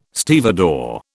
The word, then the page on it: stevedore: British English